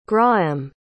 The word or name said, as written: grahem